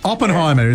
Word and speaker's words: Oppenheimer: Oppenheimer is how it's pronounced.